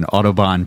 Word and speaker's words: Audubon: We are recording live today at Bald Birds Brewing in Audubon, Pennsylvania. Did I pronounce that right? – You did, yeah.